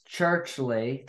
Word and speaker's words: Churchley: On the Show today, I'm happy to have on Miss Churchley.